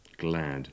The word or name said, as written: glad